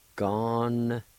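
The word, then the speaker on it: gonyegi: In this word, the A with the N will be silent, and you can pronounce the N sound with the G-A like this. Gon-ye-gi.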